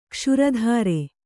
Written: ಗೋಶ್ರೋತ್ರ

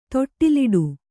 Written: ತೊಟ್ಟಿಲಿಡು

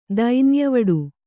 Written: ದೈನ್ಯವಡು

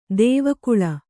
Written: ದೇವಕುಳ

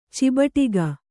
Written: ಚಿಬಟಿಗ

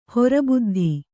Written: ಹೊರಬುದ್ಧಿ

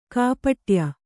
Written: ಕಾಪಟ್ಯ

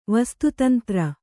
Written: ವಸ್ತುತಂತ್ರ

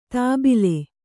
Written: ದಬ್ಬಳ

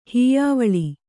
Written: ಹೀಯಾವಳಿ

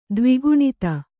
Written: ದ್ವಿಗುಣಿತ

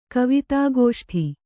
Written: ಕವಿತಾಗೋಷ್ಠಿ

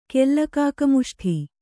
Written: ಕೆಲ್ಲಕಾಕಮುಷ್ಠಿ

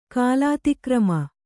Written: ಕಾಲಾತಿಕ್ರಮ

